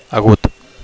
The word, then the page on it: agut: Catalan